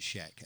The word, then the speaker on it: shag: Though it's spelt shag. Anyway. You pronounce it Shek.